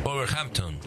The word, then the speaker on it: Wolverhampton: Wolverhampton. – Wolverhampton.